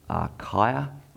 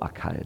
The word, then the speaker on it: Achaia: is Achaia or Achaia, depending on which pronunciation you want to use.